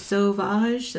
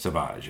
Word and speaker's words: Sauvage: Sauvage. That's how you say it. – Sauvage.